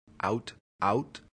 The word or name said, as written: aut aut